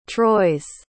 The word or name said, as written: troyes